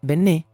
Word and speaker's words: JonBenét: el cual por cierto se escribe Bennett pero se pronuncia Bené por un acento francés que lleva en la penúltima letra.